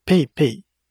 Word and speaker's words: PayPay: ば支払いができるっていうね、そういうとこなんだけど、それプラス、最近になってやっとね、 PayPay にも対応して、クレジットカードはま使えないお店なんだけど、 PayPay が使えるから、あ、 PayPay って PayPay。発音どどうやって言うんだっけ ?PayPay。 Pay、 p a y なんか、下げるんだよね。